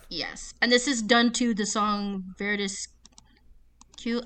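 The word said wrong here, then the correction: Veridis Quo: Verdis Quo.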